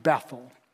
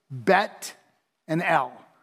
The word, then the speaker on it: Bethel: I know we pronounce it Bethel. It's actually a Hebrew compound word. And so I'm pronouncing it today like a Jew with Hebrew would pronounce it, Bet and El.